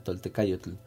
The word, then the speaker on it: Toltecayotl: Em, se escribe así como suena Tolte-K con C de casa, yo con Y y tl, TL al final, Toltecayotl